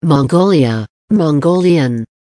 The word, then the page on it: mongolia mongolian: American English